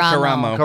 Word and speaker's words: Karamo: Karamo Brown? Is that how it's pronounced? – Karamo.